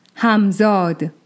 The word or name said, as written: Hamzād